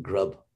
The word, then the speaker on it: grabh: They pronounce it grub, G-R-A-B-H, but it actually means grab.